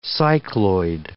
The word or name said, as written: cycloid